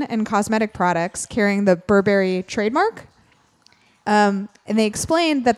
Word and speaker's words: Burberry: Am I saying it wrong? – No, I was just putting a different. – Okay. I don't know how it's Burberry. – Pronounced. Burberry. Okay.